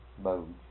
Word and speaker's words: Bogue: Bogue. – It's B-O-G-U-E. – Mm-hmm. – Bogue. – Bogue. I guess that's the way you pronounce it.